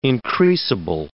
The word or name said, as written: increasable